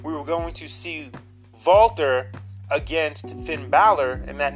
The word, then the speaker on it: Walter: Yes, it's pronounced Walter, you fucking idiots who call him Walter.